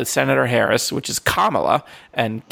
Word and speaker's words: Kamala: It's because because because Senator Harris, she uh, her mom is is Indian, so the emphasis is mm. different. – She's Kamala Harris, Kamala mm. Khan is Kamala, so it's it's the difference between the first syllable and the second one.